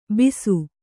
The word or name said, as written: ಬಿಸು